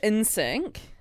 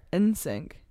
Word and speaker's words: NSYNC: NSYNC. – NSYNC. – The whole reason why they call themselves NSYNC, because they took out the I, so you don't have to pronounce the I, NSYNC.